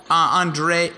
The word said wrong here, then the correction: Andrade: I can't say his name Andrade.